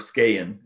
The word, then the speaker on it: Skagen: If I reflect on the brand like Skagen, we did, or Skagen as it's actually supposed to be pronounced in Denmark.